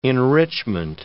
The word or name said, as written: enrichment